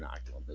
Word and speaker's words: Inoculum: think Fear Inoculum is probably correct.